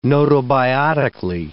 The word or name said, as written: gnotobiotically